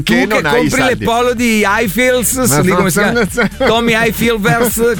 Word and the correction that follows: Tommy Hilfiger: come si chiama quello che non si sa a pronunciarlo? – Tommy Hilfiger. – Ecco, bravo.